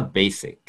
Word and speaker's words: basic: まあ、カタカナでベーシックって言ったりしますけれども、英語の発音としては basic。